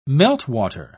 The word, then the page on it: meltwater: British English